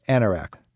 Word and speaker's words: anorak: Now, sometimes somebody, it's bad weather out, and they want to put on their anorak. Am I pronouncing that right? Anorak. – You're pronouncing that exactly right.